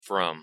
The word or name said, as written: from